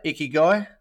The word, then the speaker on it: Ikigai: Mate, is that how I say it, Ikigai? – That's how you say it.